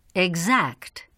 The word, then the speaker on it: Exact: The letter X is sometimes pronounced as g plus z. Repeat these examples. – Examine. Exact.